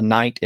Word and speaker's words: knight: It has six letters, but only three of them are pronounced. The N, the I, and the T, knight. There's a K at the front, which represents a sound that was there at one time in Old English. And the GH in the middle represents a sound that was once there. So the way it would have been pronounced would have been more like knicht.